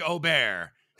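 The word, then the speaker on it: Åberg: I like that you got Ludwig's pronunciation right, though. – Thank you. Thank you. – Ludwig Aubert. Because it's been Aberg, you know, but.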